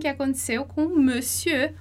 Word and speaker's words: monsieur: E aí, veja que, por conta do uso, acontece que a escrita não tem mais nada a ver com a pronúncia, tá? Então, monsieur, senhor, e a gente fala monsieur, monsieur.